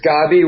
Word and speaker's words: Gabby: So that's but it's pronounced Gabby. It's a Hebrew name.